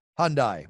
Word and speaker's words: Hyundai: Hyundai.